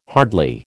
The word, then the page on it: hardly: American English